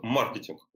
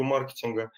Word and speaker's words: маркетинг: Во-первых, маркетинг, ударение на «ма». Как э, говорят маркетологи, а, маркетинг – это плохой маркетинг. А, поэтому э, принято ударение именно на первый слог.